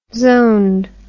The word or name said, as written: zoned